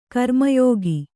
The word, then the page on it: ಗ್ರಾಮ್ಯಕ: Kannada